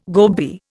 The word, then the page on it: gobby: Indian